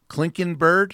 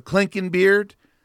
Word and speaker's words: Klinkenbeard: So when Crystal Klinkenbeard, I think the C-SPAN host mispronounced her name, he said Klinkenbird. I mean, that's weird too. But Klinkenbeard, when she says that I'm exaggerating this, oh, really?